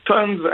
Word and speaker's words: Tons: Fait que c'est TONS, T-O-N-S. Puis duo rhum, donc euh, comme, comme ça, se prononce. là. – c'est ça. si vous dites tonnes à la SAQ, ils vont trouver ça très rapidement.